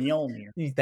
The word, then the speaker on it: Mjolnir: even taking control of Mjolnir, or Magic Hammer, I can never say it correctly. But she takes Mjolnir. – Thank you.